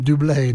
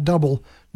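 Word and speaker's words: Bublé: That's how it's pronounced, I understand. Dublé, not double. Dublé.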